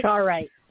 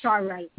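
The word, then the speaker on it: charoite: It's C-H-A-R-O-I-T-E, and it's for the heart. – Charite. – Charite? Oh, Charite. So now you know, John.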